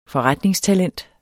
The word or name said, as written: forretningstalent